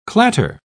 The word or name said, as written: clatter